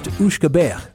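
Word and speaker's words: uisge beatha: It was originally pronounced Ushkabeh, then simply Uski, without the E.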